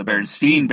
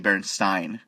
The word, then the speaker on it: Berenstain: Ooh, just like the Berenstain Bears, but all through childhood those books, the Berenstain Bears, but it's actually Berenstain. We've been saying it wrong the entire time.